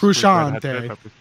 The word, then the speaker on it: Frusciante: Frusciante. Frusciante. That's how you pronounce it.